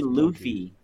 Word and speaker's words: Luffy: Luffy.